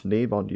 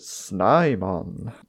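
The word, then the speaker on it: Snimon: Yeah, it's pronounced Sneemon usually, but he calls it Snaimon.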